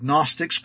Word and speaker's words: Gnostics: Now, that is spelled with a G, G-N-O-S-T-I-C-S. The G is silent.